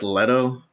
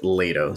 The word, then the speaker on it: Leto: So Oscar Isaac plays Duke Leto, and yeah, it's pronounced, or it looks like Leto. I read that it's actually pronounced Leto, so whatever reason.